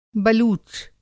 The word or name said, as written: बलूच